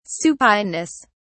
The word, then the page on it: supineness: British English